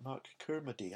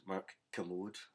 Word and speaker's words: Mark Kermode: Mark Kermode or is it Mark Kermody? I'm never sure quite how to pronounce his name. Kermode? – He likes to be called Mark Kermode.